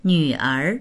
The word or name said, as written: nv3er2